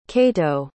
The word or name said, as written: cato